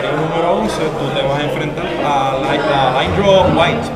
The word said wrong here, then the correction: Lenor White: perdóname. – Lindro White. – ¿Y cómo se dice? Perdón. – Lenor White. – Ah, Lenor White.